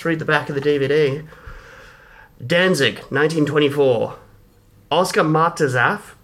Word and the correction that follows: Matzerath: I've had such the hardest time learning to pronounce that name. Oscar Matzerath. There we go.